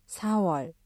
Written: 사월

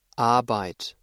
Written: Arbeit

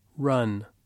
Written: run

